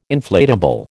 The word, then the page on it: inflatable: American English